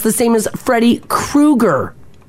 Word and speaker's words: Kroeger: Is he a Kruger? – He's a Kruger. Chad Kruger. – Yeah, speaking on a recent podcast, Kruger, or I can't even say it, because I've always said Chad Kroeger.